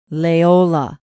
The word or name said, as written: leola